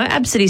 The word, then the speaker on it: Abcde: Absidy sounds all right, but it's spelt A-B-C-D-E. It's the first few letters of the alphabet, Absidy.